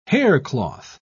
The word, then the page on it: haircloth: British English